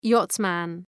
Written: yachtsman

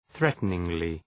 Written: threateningly

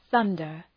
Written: thunder

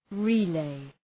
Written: relay

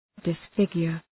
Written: disfigure

